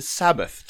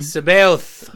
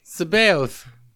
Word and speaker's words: Sabaoth: You said it wrong. – I know it's not Sabbath, but it's Sabaoth. I don't know how do you pronounce that? – Sabaoth. – No. – That's how you say it.